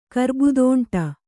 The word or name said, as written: ಕರ್ಬುದೋಂಟ